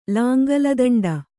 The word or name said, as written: ಲಾಂಗಲದಂಡ